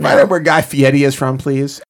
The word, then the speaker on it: Fieri: She was Southern like the guy Fieri. Is that how you pronounce it? – Yeah, Fieri.